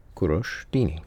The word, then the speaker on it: Kurosh Dini: Kurosh Dini. So, ku is like the word who with a K in front. Easy enough. Rosh is probably the toughest part, where the vowel is somewhere between like rough and ro.